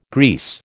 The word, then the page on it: grease: American English